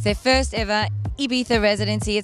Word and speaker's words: Ibiza: but recently you had a nice lengthy stay over in, I'm going to pronounce this right, Ibiza.